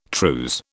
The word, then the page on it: trews: British English